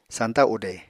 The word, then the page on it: sañta ude: Apatani